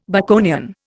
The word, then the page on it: Baconian: Indian